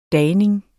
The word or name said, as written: dagning